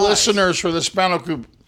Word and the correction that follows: spanakopita: Spanakopita, this is awesome. – Don't make me tell you how to pronounce Greek if you can't pronounce it yourself. So these are spanakopita.